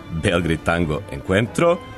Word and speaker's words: Belgrade Tango Encuentro: U toku je treći internacionalni festival argentinskog tanga, Belgrade Tango Encuentro, ja se nadam da sam ovo ispravno izgovorio, koji od svog nastanka okuplja veliki broj zaljubljenika u ovu muziku i ples. Tim povodom sa nama u studiju je Petar Jelača, jedan od organizatora. Petre, dobar dan, i ispravi me odmah na početku. – Dobar dan, da, u pitanju je četvrto izdanje festivala Belgrade Tango Encuentro, dobro si izgovorio, i moram da kažem da si jedan od retkih koji prvi, ovako na prvo čitanje, to pravilno ispale kako treba.